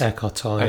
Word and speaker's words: Eckhart Tolle: And I saw this question of Eckhart Tolle. Am I pronouncing his name right? – Eckhart Tolle, yeah.